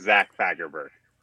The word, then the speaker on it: Zach Fagerberg: My name, my full name is Zach Fagerberg.